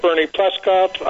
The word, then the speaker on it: Bernie Pleskoff: Yes, you did, and you can follow me on Twitter at Bernie Pleskoff.